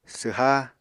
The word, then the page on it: swha: apatani